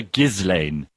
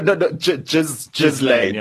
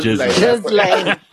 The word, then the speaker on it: Ghislaine: You said he calls her Ghislaine. – No, Ghislaine. – Yeah, sorry, Ghislaine. So now when people say Ghislaine or whatever else, I'm like, excuse me, it's Ghislaine.